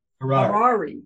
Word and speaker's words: Harary: Harary? How would I say that, Dan? – Harary. – Harary,